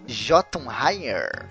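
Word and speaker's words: Jötunheim: Então, a pronúncia de Jotunheimer é Jotunheim mesmo, e não Heim.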